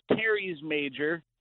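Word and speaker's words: teres major: It's actually pronounced Terry's Major. There is a minor, and those are two out of the four muscles that make up your rotator cuff.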